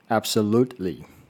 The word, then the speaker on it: absolutely: Absolutely, absolutely。那这个字的发音，各位可以注意一下，就是呃，念很标准的话，absolutely 那个 t，很多时候会有些人会念的太用力，因为很认真想要念好，所以会念的太用力。